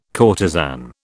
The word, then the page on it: courtesan: British English